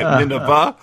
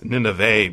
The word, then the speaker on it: Nineveh: So that's the very first thing that strikes me is I'm pronouncing Nineveh wrong. I'm calling it Nineveh, and it's Nineveh because he rhymes it with gray.